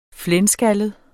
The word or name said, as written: flenskaldet